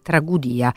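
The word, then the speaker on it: Tragùdia: Ricorderete per esempio il suo Macbeth, stavolta è Tragudia, scusatemi l'accento è sulla U,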